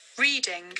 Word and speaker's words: Reading: Just Reading.